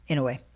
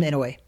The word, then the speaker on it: Inoue: But it's, in a way, how do you say his first name? Naoye? Naoye? – Inoue. – That's, everybody knows him, Inoue.